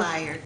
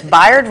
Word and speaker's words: Bayard: Was I saying it wrong, Bayard? Is it Bayard Rustin? – Bayard. – Okay, see, I learned something today. It's Bayard Rustin.